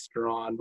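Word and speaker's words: Strachan: It's called Strawn, but it's spelled like Straghan or something.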